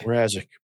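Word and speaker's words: Mrázek: Mrazek? Peter Mrazek? – Mrazek.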